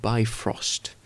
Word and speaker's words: Bifrost: En nou ja, het wordt ook in Marvel wordt het zo uitgesproken, Bifrost.